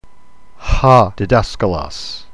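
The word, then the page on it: διδάσκαλος: didaskalos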